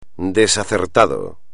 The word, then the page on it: desacertado: espagnol